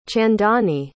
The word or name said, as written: chandani